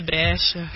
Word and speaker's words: Brescia: Dillo bene con l'accento giusto. – Brescia. – Ecco, così sì.